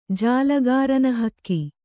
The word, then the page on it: ಜಾಲಗಾರನಹಕ್ಕಿ: jālagārana hakki